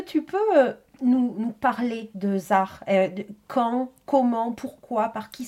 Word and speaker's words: Zaj: Tu as parlé du groupe ZAR, donc ça s'écrit ZAJ. Oui. Hein. C'est on a parlé la dernière fois de ce J espagnol.